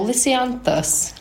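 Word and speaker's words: Lisianthus: No, Lysianthus. That's how you pronounce it.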